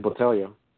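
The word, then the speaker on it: Polyana Botelho: Pearl Gonzalez taking on UFC newcomer Poliana Batijo. You know how I said that correctly? – I think it's Botelho.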